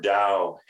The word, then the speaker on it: Tao: Well, first of all, you pronounced Tao correctly.